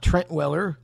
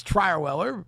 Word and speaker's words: Trierweller: It's not Trent Weller like it's in the program, but it's Trierweller, so we'll say it right.